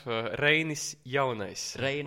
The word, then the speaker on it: Reinis Jaunais: Reinis Jaunais would be, yes, like super correct way, well, the way Latvians would say it.